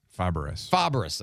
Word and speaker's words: Fabares: We had text, follow-up text on Shelley, how do you say it? Fabares? Is that how you say her name? Fabares? – Fabares. – Okay. Yes.